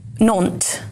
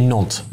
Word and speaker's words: Nantes: Nantes. – Okay, Nantes.